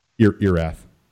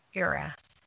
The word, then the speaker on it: Erath: Erath. – Erath.